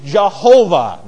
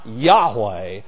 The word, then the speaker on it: Jehovah: Y-H-W-H. properly pronounced in the English language Jehovah, not Yahweh or Yehovah or Yehovah or whatever. And by the way, there's a reason for that that I am not going to go into now, but I used to think that I was more biblical by pronouncing it with a Y.